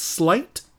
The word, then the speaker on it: Sleight: It's called Slight. It's spelled S-L-E-I-G-H-T. Mm. And Slight, I think, is one of the better sort of independent, original, quasi-superhero indies that we've had.